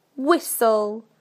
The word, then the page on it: whistle: British English